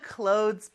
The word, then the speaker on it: clothes: Put it in the clothes box. 자, clothes 발음이 조금 어려울 수도 있는데요. 잘 따라해볼까요? Clothes, clothes. 네, 그렇게 끝에 z 발음 나는 거 많이 연습해주세요.